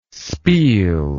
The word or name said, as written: speel